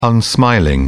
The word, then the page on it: unsmiling: British English